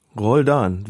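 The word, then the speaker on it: Roldán: Roldan, vielleicht Betonung auf der zweiten Silbe, das mag sein, ja.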